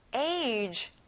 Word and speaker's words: age: 나이, age. 친구들, 여기는 G에 G 소리가 아닌 J 소리가 나요.